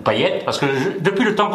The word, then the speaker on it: Payet: Non, non, c'est à La Réunion d'où je viens, ça se dit Payette.